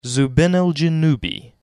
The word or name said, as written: Zubenelgenube